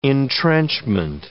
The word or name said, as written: entrenchment